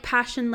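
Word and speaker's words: Pashion: It's spelled like passion, like fashion. – Yeah. – Passion. Like fashion with a P.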